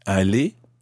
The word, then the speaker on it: aller: A-L-L-E-Z. – Est-ce que vous allez aller? – A-L-L-E-R. – Good. Okay, they sound exactly the same. Aller, E-Z. Aller, E-R.